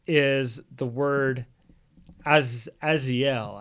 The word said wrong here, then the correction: Azazel: I think, is how you say it. Azazel, Azazel is okay. how we say it.